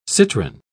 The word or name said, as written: citrine